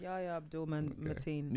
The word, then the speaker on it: Yahya Abdul-Mateen: Yahya Abdul-Mateen.